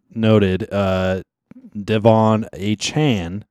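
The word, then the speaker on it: Achane: It's not A Chain which is much cooler. We all agree it's very much much cooler. He wants it pronounced a Chan. – Chan, like a Jackie Chan.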